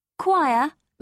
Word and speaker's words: choir: Choir must always rhyme with wire. That again will rhyme with liar.